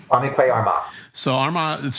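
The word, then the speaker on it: Kwame Kwei-Armah: Kwame Kwai Armal.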